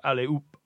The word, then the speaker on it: allez-oop: It comes from H-O-P, ope. It sounds like ope in French, because you don't pronounce the H. So allez-oop, allez-oop, like that. So it sounds like up.